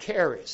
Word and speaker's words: charis: The Bible itself uses grace in other ways, because grace comes from the Greek word charis, C-H-A-R-I-S, pronounced charis or charis.